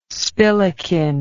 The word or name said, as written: spillikin